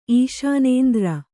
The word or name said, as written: ಈಶಾನೇಂದ್ರ